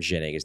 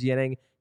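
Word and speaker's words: Ginning: That's actually the way you're supposed to pronounce his name is Yining. He said he wants to be called Jennings, so fine, you're Jennings.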